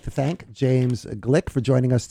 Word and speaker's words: Gleick: And while it's pronounced Gleick, it's spelled G-L-E-I-C-K.